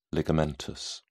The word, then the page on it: ligamentous: British English